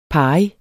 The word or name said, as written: pari